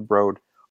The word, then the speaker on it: Broad: Because I have to say, like, even if I say it like politely, like, oh, just so you know, they pronounce it the Broad.